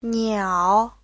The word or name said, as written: niao3